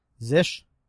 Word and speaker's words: zsh: I'm using, I don't know how you say it, it's Zish, Z-S-H.